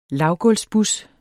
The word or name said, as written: lavgulvsbus